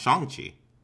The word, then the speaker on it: Shang-Chi: Which I learned from Kevin Feige is that you pronounce Shang-Chi.